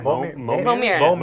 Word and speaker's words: Momir: And it is going to be the Extra Life Momers Madness. And what's a Momer? – What's a Momer? – Momir. – Oh, I accidentally said it wrong.